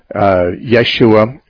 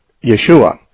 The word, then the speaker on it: Yeshua: In Aramaic, it would probably be pronounced something like Yeshua, Yahshua, Yehoshua, or something very similar to that.